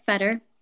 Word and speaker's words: Fetter: Is it Vetter or Fetter? – Yeah, it's Fetter.